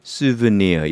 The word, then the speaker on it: souvenir: 그럼 왜 발음을 souvenir 이렇게 했냐면은 프랑스에서 온 말이고요. 이게 프랑스예요. 그래서 프랑스는 souvenir 이렇게 읽기 때문에 발음이 souvenir라고 프랑스식으로 읽는 겁니다.